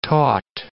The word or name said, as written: taut